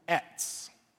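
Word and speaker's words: etz (עץ): It's important for us to understand that this word for piece of wood is actually the word in Hebrew etz. Kind of E-T-Z is how you would spell it phonetically, I guess. Etz.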